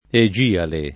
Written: Egiale

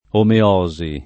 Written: omeosi

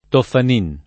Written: Toffanin

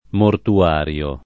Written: mortuario

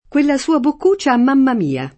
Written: mamma mia

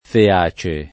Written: feace

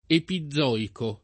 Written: epizoico

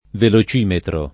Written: velocimetro

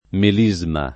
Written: melisma

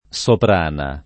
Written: Soprana

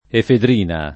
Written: efedrina

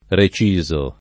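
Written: recidere

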